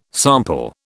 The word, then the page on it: sample: British English